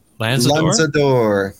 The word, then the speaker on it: Lanzador: Lanzador.